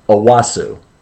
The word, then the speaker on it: Onwuasor: When I say it to Maureen, because I want her to spell it correctly when we're starting our score sheets, I say Onwuasor, so she spells it out correctly, phonetically here, but it's Owasu.